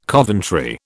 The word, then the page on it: Coventry: British English